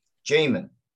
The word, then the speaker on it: Jaman: Hey, how do you say your name? – Jaman. – Jaman. – Yeah. – Not J-Man. – Right. – Okay. – But you can, either way, it doesn't matter.